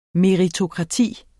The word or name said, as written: meritokrati